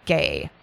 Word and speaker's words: Gey: And these samples were given to a physician and cancer researcher at Johns Hopkins, a man named George Otto Gay. I think it's pronounced gay, G-E-Y.